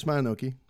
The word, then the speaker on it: Mononoke: Mononoke, am I saying that right? – Yeah, Princess Mononoke. That's fine.